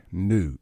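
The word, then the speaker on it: Nooe: It's Allison Lee, L-E-A, New, N-O-O-E. I said it wrong last week. I said Allison Lee Noon and got corrected by another mutual friend of ours.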